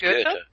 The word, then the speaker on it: Goethe: I think it's Goethe. – Sounds good. – Goethe, there's more of an R sound to it. Goethe.